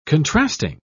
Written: contrasting

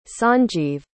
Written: sanjiv

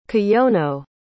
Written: Kiyono

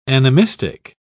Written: animistic

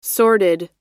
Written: sorted

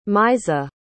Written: maizah

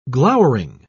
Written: glowering

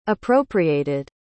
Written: appropriated

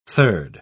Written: third

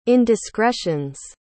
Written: indiscretions

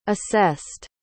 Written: assessed

